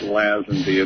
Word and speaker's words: Lazenby: Jack Kent Cook told me once years ago that I didn't know how to pronounce my own name. So I don't feel bad about calling it Lazenby. It's not the only time that pronunciation's been used.